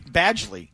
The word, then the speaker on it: Badgley: Badgley. That's how you pronounce it.